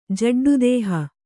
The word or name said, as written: ಜಡ್ಡುದೇಹ